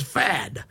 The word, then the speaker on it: Pfaadt: P-F-A-A-D-T. – And both the P Fad. – And the T are silent. – Right. – So it's Fad.